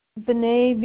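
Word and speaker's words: Veney: Denise, Vinay, V as in Victor, E-N-E-Y.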